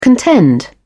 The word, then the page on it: contend: British English